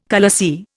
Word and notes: khalasi: Indian

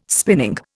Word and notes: spinning: Indian

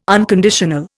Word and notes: unconditional: Indian

